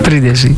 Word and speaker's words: Πρίντεζι: Όπως το προφέρει ή όπως το. – Όχι, όχι, το λέει καλύτερα. – Στο Πρίντεζι. – Πρίντεζι. – Κανονικά. Α, σαν και εμένα το λέτε κι εσείς.